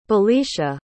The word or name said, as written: belicia